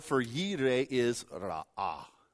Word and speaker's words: Yireh: Now, if you've been around the church for any length of time, you've probably heard the Greek pronunciation, and that is Jehovah Jireh. Jehovah Jireh, our provider. It's an absolute butcher of the Hebrew, so stop saying it if you say that.